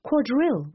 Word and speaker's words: Quadrille: Quadrille was to be pronounced with the accent on its last syllable, Philistine on its first.